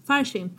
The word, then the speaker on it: Farsin: Så du uttalar ditt namn rätt. – Farsin. – Farsin. – Ja. Farsin.